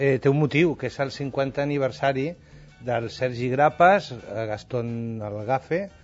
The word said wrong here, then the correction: Lagaffe: Gaston Lagaffe, no? Es diria en francès.